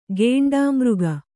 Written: ಘೇಂಡಾಮೃಗ

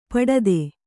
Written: ಪುಟಿತ

